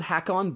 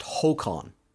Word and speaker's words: Håkon: It's spelled Hakon, but it's pronounced Håkon. – Håkon, okay. – Yeah, as long as we're trying to get Norwegian pronunciations right.